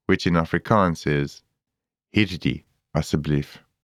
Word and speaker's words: asseblief: In Afrikaans, please is asseblief. Let's break it down by syllable. A, s, Asseblief.